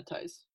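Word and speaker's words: Theis: And I think Daniel, how do you say his last name, Tice? – Yeah, Tice.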